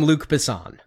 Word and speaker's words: Luc Besson: But one of the writers, and it's French, I'm not quite sure how to say it, Luc Besson. You kind of just slur at the end of it to make it sound French. – I think Americans call him Luc Besson.